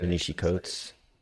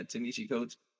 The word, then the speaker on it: Ta-Nehisi Coates: Warner Brothers is reportedly looking into introducing a Black Superman for a new film written by Ta-Nehisi Coates. If I said that right, I probably did. – Ta-Nehisi Coates. – Yeah, Ta-Nehisi Coates.